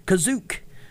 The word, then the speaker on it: Kashyyyk: In the special, Kashyyyk is pronounced Kazook and is spelled that way in the script, reflecting Lucas's earliest notes on the Wookiee homeworld.